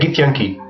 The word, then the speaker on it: Githyanki: Deixa eu dizer, a pronúncia correta é Githyanki.